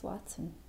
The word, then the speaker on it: Watson: Eileen Burgess Watson. – Okay, can you spell that? Spell Watson? – W-A-T-S-O-N? – Yeah.